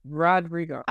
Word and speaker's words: Rodrigo: Rodrigo. – I pronounce Rodrigo. Hold on. Who? Because the W be coming out of nowhere. I'm telling you, after the DR, the W be coming out of nowhere.